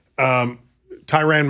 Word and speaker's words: Tyrann: Tyrann Mathieu. Did I pronounce his first name right? – Yeah.